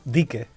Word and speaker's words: Dique: Dique. – Dique Dominican. – Just drop your S.